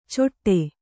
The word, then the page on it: ಚೊಟ್ಟೆ: coṭṭe